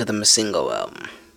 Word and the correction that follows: Masego: Ain't it Masego?